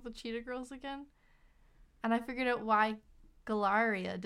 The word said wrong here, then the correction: Galleria: didn't sound familiar. It's because her name is Galleria.